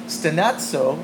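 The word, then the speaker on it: stenazo (στενάζω): I mentioned that that particular word in the original Greek actually means, it's pronounced stenazo, which is the next slide, which means to murmur or pray inaudibly with grief or to groan.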